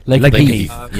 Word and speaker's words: Lakenheath: Raptors from the 1st Fighter Wing at Joint Base Langley-Eustis in Virginia arrived at Royal Air Force Lakenheath, U.K. Did I pronounce that right? – Lakenheath. Yeah.